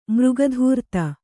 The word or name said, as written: ಮೃಗಧೂರ್ತ